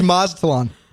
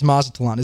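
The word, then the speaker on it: Mazatlán: Mazatian. – Mazatian. – No, it's Mazatlan. – It is? – It's Mazatlan, isn't it? Mazatlan? See the silent T there?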